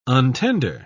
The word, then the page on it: untender: British English